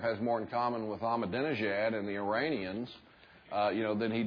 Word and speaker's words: Ahmadinejad: And there's that little guy, it took me about 3 months to learn to say his name, Ahmadinejad. Ahmadinejad, you know.